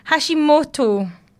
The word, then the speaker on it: Hashimoto: And it's Hashimoto.